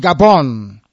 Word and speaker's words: Gabon: In Gabon, where the French pronunciation is Gabon. That is the way it's pronounced in French.